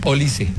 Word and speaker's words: Olise: La correcta pronunciación es Olise.